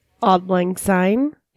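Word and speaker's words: Auld Lang Syne: Once midnight hits, it's tradition to sing a song that I'm going to mispronounce, Odd Long Sin. – Odd Lang Syne.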